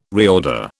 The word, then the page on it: reorder: British English